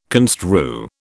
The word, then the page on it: construe: British English